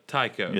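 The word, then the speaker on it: Taiko: And the I say it wrong every time. Hold, please. Taikyo? Tampa Tycho.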